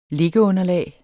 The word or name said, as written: liggeunderlag